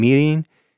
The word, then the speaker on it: mirin: There's a mixture that they make of soy sauce and mirin. I should probably say what that is. I mentioned that before, and I'll say it like an American. Mirin, M-I-R-I-N, is, you can kind of think of it, it's a little similar to sake, but it would be sweeter and less alcoholic.